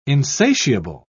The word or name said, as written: insatiable